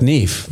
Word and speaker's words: Knef: Ich glaube, du darfst sie auch Knef nennen. Ja, aber das K spricht man ja nicht.